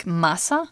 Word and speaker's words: Mahsa: Yeah, and the police there said Masha did I pronounce the name well? I think Masa.